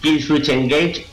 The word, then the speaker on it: Killswitch Engage: Kill Witch en Jane. ¿En Jage? ¿Pronuncio bien? En Jage. – Kill Switch Engage, Rose of Setting.